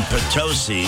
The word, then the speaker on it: Potosí: The game in Potosi, which rhymes with another unpleasant word,